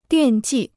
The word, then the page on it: 惦记: diàn jì